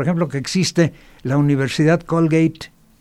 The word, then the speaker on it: Colgate: familia Colgate, que es la pronunciación correcta en inglés, y fundaron toda una universidad y la dejan con fondos para que, y tiene, no sé, cerca de 100 años esa universidad.